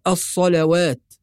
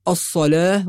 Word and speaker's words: الصلاة: فكلمة الصلاة لو نظرنا إليها في القرآن الكريم نجد أنها مرسومة بالواو، فبعض الناس يظن أنها تقرأ الصلوات، والصحيح أنها تقرأ بالألف. ولو دققنا النظر في الكلمة المرسومة في القرآن نجد ألف صغيرة وضعت فوق الواو، فيكون النطق الصلاة وليست الصلوات.